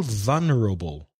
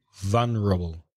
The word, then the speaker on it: vulnerable: V-U-L-N-E-R-A-B-E, vulnerable. – It's the same spelling in Spanish, isn't it? But it's quite different pronunciation in English. – How do you say it in Spanish? – Vulnerable. – Different stress, vulnerable. So the stress is on the first syllable in English.